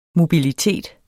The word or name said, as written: mobilitet